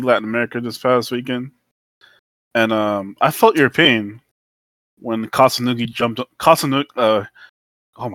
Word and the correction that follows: Kusanagi: my God, I can't pronounce his name at the moment. But Kasunugi. Oh my God. Holy shit. Greg, you know. – Kusanagi. – Kusanagi, there you go.